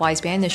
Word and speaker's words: Wise Banyan: And I'm going to spell this, by the way, just so if somebody's listening, whether so Wise Banyan, W I S E B A N Y A N. I mean, it's pronounced just like it looks, but Wise Banyan.